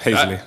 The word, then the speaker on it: Hazeley: Have I said your name right? – Close enough, Hazellee. – Damn it.